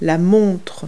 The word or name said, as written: watch